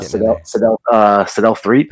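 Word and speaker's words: Sedale Threatt: He was always getting Sadel Threet. Is that how you say his name? – Yes.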